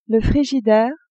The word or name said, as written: frigidaire